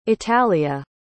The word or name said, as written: italia